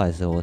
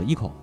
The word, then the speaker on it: ICO: 你听他那采访的时候，视频采访里边，他们自己发音不叫 ICO，他叫那个 Eco，就叫 Eco，因为这个是两个词词，一是那个 ICON。一孔那个词是就是有偶像这个意思，圣像偶像在里边，还有一个就是日本的这个就日文这个 eco 这个词儿合在一起，所以就叫 eco。